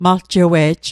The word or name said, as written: maljewe'j